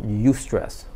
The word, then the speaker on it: eustress: Yeah, so that's sweet spot is called eustress. Again, everything's about you, but in this case it's spelled EU.